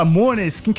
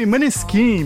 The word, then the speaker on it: Måneskin: A gente fala Måneskin, que é o nome oficial com a pronúncia italiana, mas aqui no Brasil o pessoal chama de Måneskin mesmo, daquela música do Begin, que toca aí todo o rolê, toda a balada.